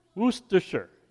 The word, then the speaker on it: Worcestershire: Worcestershire. – Worcestershire. Worcestershire, yes.